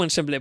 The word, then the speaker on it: Ensemble: Eh, ¿Ensemble o ensemble? Porque teníamos la duda. – Estábamos comentándolo antes, ¿cómo se pronunciaba? Ensemble, ensemble, perfecto.